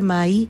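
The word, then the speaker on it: mãe: Se pronuncia May y se escribe Mae.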